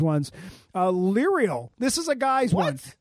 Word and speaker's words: Leriel: L-E-R-I-E-L. It's like Ariel, but it's Lerial.